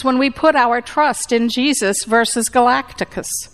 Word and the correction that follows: Galactus: Excuse me, Galactus. I always want to add an extra syllable there.